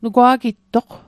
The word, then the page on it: nugwa'gittoq: Mi'gmaq